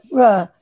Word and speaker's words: Freur: They were called, and you were supposed to say it as a sound, not as a name. They were called Bruh. And you're supposed to say it like Bruh. Actually, it was spelled F-R-E-U-R.